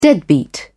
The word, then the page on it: deadbeat: British English